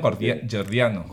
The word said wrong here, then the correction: gordiano: Gordiano. – Gordiano, sí, eso.